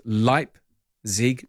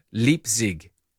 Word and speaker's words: Leipzig: The German pronounced the second vowel, so it's Leipzig, not Leipzig. Regard says Otto.